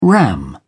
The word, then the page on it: ram: British English